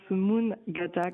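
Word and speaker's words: mångata: Il y a plein de gens qui disent Mangata, mais c'est vrai que c'est pas évident parce que c'est avec un A au début, mais avec un petit rond sur le haut du A, et donc ça se prononce Mangata comme à la suédoise.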